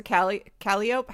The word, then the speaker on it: calliope: I think it's a calliope. How do you say that? – I don't know. – Because it says calliope. – A calliope? – Calliope. Thank you.